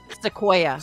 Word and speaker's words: Sequoia: Sequoia.